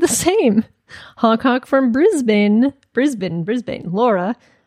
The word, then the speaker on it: Brisbane: PPS, it's Brisbane, not Brisbane. Or affectionately, Bris Vegas, like Las Vegas. – Oh, wow. – Brisbane.